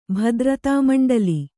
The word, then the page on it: ಭದ್ರತಾಮಂಡಲಿ: bhadratāmaṇḍali